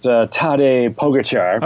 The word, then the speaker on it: Tadej Pogačar: Oh, I've been told it's pronounced Tadej Pogačar.